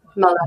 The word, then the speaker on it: Todd Muller: Um, Todd Muller, is that is that how you pronounce his name, or is it Muller? Yeah. – Muller. Muller. Um, he's quite new in the job.